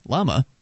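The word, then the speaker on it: bla ma: It's the name is a combination of the Mongolian word, can't pronounce that, Dalai, meaning ocean, and the Tibetan word Blama, with a silent B, Lama, meaning chief or high priest.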